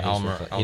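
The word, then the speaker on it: Almirón: Like Almiron, I don't know how to say his name. – Yeah, Almiron.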